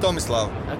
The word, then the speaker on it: Tomislav: I'm Tomislav. – Okay. How do you spell that? – Like T-O-M I-S-L-A-V.